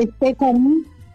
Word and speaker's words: Stephanie: Alexandra Estefan.